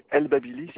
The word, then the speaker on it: El Babili: C'est El Babili, ça s'écrit E-L espace B-A-B-I-L-I.